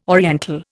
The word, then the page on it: oriental: Indian